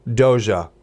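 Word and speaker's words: Dogue: It's called Doja. It's pronounced like dog, but with this snooty French person, an accent kind of to it and everything.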